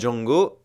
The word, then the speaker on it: Django: En effet, lorsque Django se présente en version originale, il épelle son nom, D-J-A-N-G-O, puis précise The D is silent.